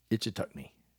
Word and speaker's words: Ichetucknee: I hope I'm saying Itch Itchitunkey Springs. Good grief. Let me try this one more time. Ichetucknee. I did it.